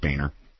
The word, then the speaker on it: Boehner: Julie in Seattle has sent me it's a press release right from the boner. From really? – House Republican Leader John Boner. Hi, Steph. – I believe it's pronounced Boehner. – When two vowels go walking, the first one does the talking.